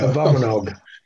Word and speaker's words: Abominog: Abominog.